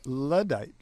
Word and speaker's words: Luddite: I said Luddite when I should have said Luddite, like you said.